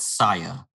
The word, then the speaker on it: Sia: I thought they pronounce that Sia. – Oh, they might. – It's Sia.